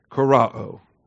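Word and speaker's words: Korao (κυρόω): Karao, K-U-R-O-O. It's got the Omicron Omega ending, so it's got both of the O's in the Greek alphabet, the short O and the long O, Korao. That's why when I transliterate, I put that horizontal line over top of that second O so that you know it's a long O.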